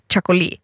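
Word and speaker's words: txakoli: Så finns det ett typ av vin som skrivs TX någonting. Och det ska... Det har jag lärt mig uttala. Och det är något så här chakoli. Och det värsta är att varför har man slängt in ett S? – Jo, jag vet inte.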